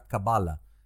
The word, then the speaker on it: Kabbalah: Entonces, bueno, por ejemplo, en inglés lo escribirás como K-A-B-B-A-L-A-H y en inglés incluso se pronunciaría Kabbalah. Ellos no dicen cabala, dicen Kabbalah. ¿eh? Fíjate cómo lo acentúan. En español decimos Kabbalah y lo escribimos con C-A-B alta A-L-A, sin más.